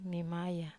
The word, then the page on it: mamaya: Wamesa